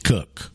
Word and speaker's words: Coke: His name is spelled Coke, C-O-K-E. It's pronounced Cook, okay?